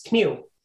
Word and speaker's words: kniv: Like knife in Danish is kniv. So K-N-I-V, you can see how it translates to, or how it became knife in English, but they still pronounce the K-N.